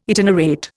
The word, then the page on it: itinerate: Indian